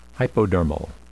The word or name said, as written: hypodermal